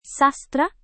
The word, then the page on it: sastra: indonesian